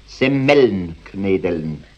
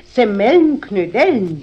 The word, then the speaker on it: Semmelknödeln: Jetzt hast du nämlich gerade gesagt, Semmelnknödeln. – Ist recht, Semmelnknödeln. – Nein. Richtig muss es eigentlich heißen, Semmelknödeln. Die Semmel muss man dabei betonen, weil doch die Knödel aus Semmel gemacht sind.